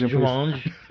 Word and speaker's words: Jumanji: It's actually pronounced Jumanji.